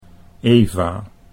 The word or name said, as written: Eva